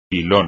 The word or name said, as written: pilón